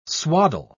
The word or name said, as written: swaddle